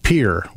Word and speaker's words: Pierre: Douglas played Pierre, which is how South Dakotans pronounce the state capital, despite how your brain says you should say it because of how it's spelled.